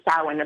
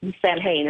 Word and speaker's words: Samhain: what they call the Samhain, or the Samhain is another way to pronounce it.